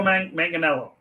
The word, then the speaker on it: Manganiello: Oh, Joe Manganiello.